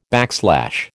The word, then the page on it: backslash: American English